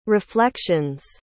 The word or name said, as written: reflections